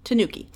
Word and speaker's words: tanuki: Tanuki.